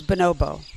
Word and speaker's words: bonobo: First of all, you should pronounce it correctly, mainly the way that it's pronounced in Africa, which is Bonobo. People pronounce it all different ways.